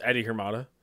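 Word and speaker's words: Eddie Hermida: It's pronounced Eddie Hermada.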